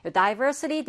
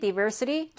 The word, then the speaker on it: diversity: diversity, diversity 둘다 발음은 가능해요. 요 di의 사운드를 die라고 하셔도 되겠고 d라고 하셔도 괜찮습니다.